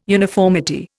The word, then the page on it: uniformity: Indian